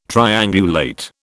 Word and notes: triangulate: British English

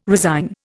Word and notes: resign: Indian

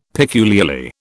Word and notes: peculiarly: British English

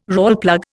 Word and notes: Rawlplug: Indian

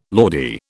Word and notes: Lordy: British English

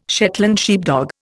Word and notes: Shetland sheepdog: Indian

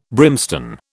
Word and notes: brimstone: British English

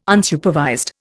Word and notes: Unsupervised: Indian

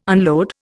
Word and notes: unload: Indian

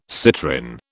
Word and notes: citrin: British English

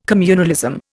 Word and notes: communalism: Indian

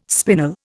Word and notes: spinal: Indian

